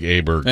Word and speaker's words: Åberg: Because I've definitely never heard of Ludvig Åberg. – And he pronounces it Åberg. How about that? – Åberg? – There's not an O there. Yeah. – Really? – Yeah. It's a Swedish thing.